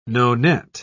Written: nonet

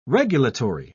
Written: regulatory

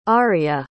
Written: arria

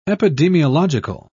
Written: epidemiological

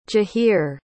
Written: jahir

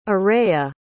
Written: arrayah

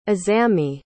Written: azami

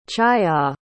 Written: chhaya

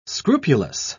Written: scrupulous

